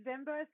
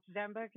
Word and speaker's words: Ziemba: Yeah, it's Ziemba. So I usually tell people to think of the Lion King and Simba, but with a Z. Traditionally, it's pronounced Ziemba because it's a Polish name.